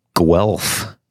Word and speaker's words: Guelph: Guelph.